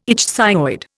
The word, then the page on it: ichthyoid: Indian